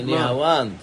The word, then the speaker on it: נהוואן: ואמרי לה זו נהר וחברותיה. – נהוואן, נהוואן, תקרא טוב. זה נהוואן. זה נהוואן וחברותיה.